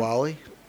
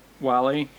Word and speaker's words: Whalley: I think it's uh, Wally. – Wally, uh, who was in the original, and she's going to be reprising her role in uh, in the series.